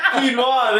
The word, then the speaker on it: quinoa: Pronouncing quine. Quinoa, there we go. It takes a mistake to remember it.